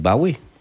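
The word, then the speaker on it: Bowie: ולדיוויד בוי את קוראת דיוויד באוי? – אגב, כן. זה האקסנט הבריטי. – דיוויד באוי. – דיוויד באוי. אני אוהב להגיד דיוויד באוי. – לא, אומרים דיוויד בוי.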